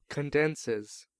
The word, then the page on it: condenses: American English